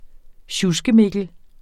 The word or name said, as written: SJUSKEMIKKEL